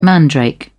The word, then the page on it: mandrake: British English